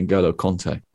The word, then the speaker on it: N'Golo Kante: You said it right the first time, by the way. It's definitely N'Golo Kante.